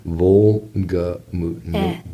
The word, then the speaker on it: Wolgemut: The W-O-L-G-A-M-U-T? – G-E. – G-E. – M-U-T. – M-U-T. – Volgamut. Being German, the W sounds like a V.